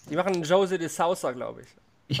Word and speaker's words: José de Sosa: weißt du, was war es? – José war richtig. José de Sosa, glaube ich. Aber das A wurde gar nicht gesprochen.